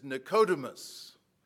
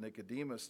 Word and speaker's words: Nicodemus: And if you, the Greek pronunciation is Nicodemus, but we anglicize it and we pronounce it Nicodemus today.